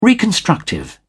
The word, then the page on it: reconstructive: British English